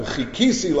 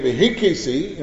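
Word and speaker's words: v'kivisi: So the Gemara says, if you come to a pasuk, v'chikisi l'Hashem, v'chikisi, I wait, or you say v'hikisi, you know, it sounds like I'm hitting, right? You can change the meaning by mispronunciation.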